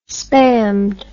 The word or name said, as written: spammed